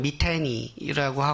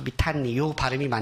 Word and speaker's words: 미탄니: 제가 이거 발음을 찾아보면 이제 영어권에서는 미테니라고 하기도 하지만 요 탄니 이렇게 해가지고 여기에 강세를 줘서 미탄니 요 발음이 맞는 것 같아요.